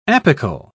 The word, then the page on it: apical: British English